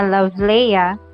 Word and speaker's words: Love Leia: Okay, ako ang full name ha, Love Leia. Ang uban maglisod og pronounce akong name asahay Lovelia, pero you can call me Love.